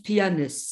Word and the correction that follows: Plains: Je ne sais pas comment on prononce. – Plains. – Ah oui, oh, pardon, sorry. – Oui, c'est Plains. – Plains.